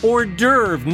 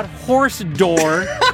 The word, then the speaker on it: hors d'oeuvre: David, the word is hors d'oeuvre, not horse door.